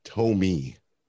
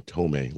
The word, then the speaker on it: Thome: For years, even before we acquired him, the pronunciation guide said the uh, first baseman for Cleveland was Jim Thome, and yet I'd hear every announcer call him Tomey, like Jim, like Mel Torme.